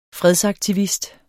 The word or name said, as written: fredsaktivist